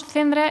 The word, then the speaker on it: Cendra: Lo pronunciamos Cendra, es que al principio poníamos la cedilla.